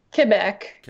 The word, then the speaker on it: Quebec: When you just called it, if I heard your accent properly, I think you refer to it as Quebec. Is that correct? – Quebec. Yeah, that's how. – Is that how you say it up there in the homeland, Quebec?